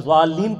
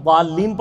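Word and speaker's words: ضالين: اس لیے کہ اگر آپ نے والین پڑھا زوئے کے ساتھ تو یہ اس کا معنی بن جاتا ہے سائے والے, سایہ دار. لیکن اگر آپ اس کو صحیح تلفظ میں والین پڑھیں گے تو اس کا مطلب گمراہ.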